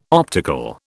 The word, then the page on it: optical: British English